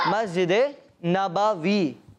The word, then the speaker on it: مسجد نبوی: جی دکھائیں اس پکچر مسجد نبوی ماشاء اللہ ان کا جواب درست ہے مسجد نبوی یہ ہے درست تلفظ